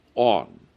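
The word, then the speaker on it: on (ἄν): There is what is called a particle in the Greek, and that is pronounced on, A-N.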